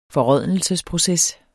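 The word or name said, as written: FORRÅDNELSESPROCES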